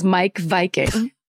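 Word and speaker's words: Meik Wiking: But then she also said that the author of that book that I said, remember it was like Miek Viking or whatever, his name is Mike Viking. And that's how it's pronounced.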